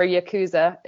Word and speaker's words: Yakuza: For those people who've never heard of Yakuza before, or Yakuza, if we're going to go with the westernized pronunciation, they are equivalent to the mafia in Japan.